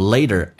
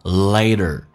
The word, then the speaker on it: later: 我们来重复一下这句话 10 years ago the only problem we faced was exam 10 years later，记住 later a 这个发音饱满一点，先发 a 再发 e a，同时 t 要被浊化，在美式发音轻轻的卷下舌头，叫 later later。